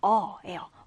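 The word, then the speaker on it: awe: 자, 이때의 이 awe라는 단어는 경외감을 뜻합니다. 발음이 그냥 awe예요.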